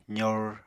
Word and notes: zor: apatani

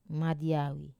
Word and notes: madiawi: wamesa

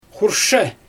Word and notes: xurʃɛ: Xyzyl

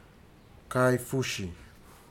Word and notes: kaifuji: futuna aniwa

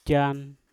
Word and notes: jan: Mpur

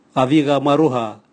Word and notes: gaviga mwarauha: Raga

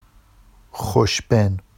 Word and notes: xoʃben: Vafsi

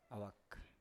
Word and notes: awak: Biak

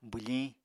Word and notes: bwfiñ: apatani

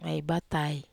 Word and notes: ai batai: Wamesa

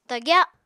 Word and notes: tagya: Apatani

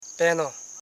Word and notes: peno: pe.no

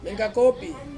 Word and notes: mengkakopi: Ansus

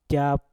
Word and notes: jap: Mpur

